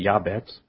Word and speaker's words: Jabez: The author of the book, The Prayer of Jabez, which is the name is pronounced Yabez, states that in the Hebrew culture, one's, quote, name is a prophetic indicator of one's life, end quote.